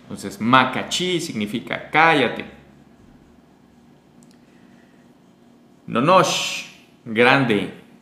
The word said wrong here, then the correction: nohoch: Nonosh, no, nohoch. Es que es una H.